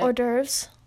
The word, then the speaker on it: hors d'oeuvres: That's horse divorce. Wait, how do you say it? You know how to say it. – Hors d'oeuvres.